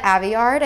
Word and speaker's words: Aveyard: By Victoria Aveyard, I think is how you pronounce her name. – Yeah, that sounds right.